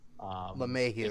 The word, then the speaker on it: LeMahieu: And I think he's gonna be the only man we have left because DJ LeMahieu, I can't say his name, LeMahieu.